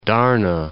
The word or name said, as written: Darnah